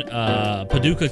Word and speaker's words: Paducah: A very sad incident happened in Paducah, Kentucky.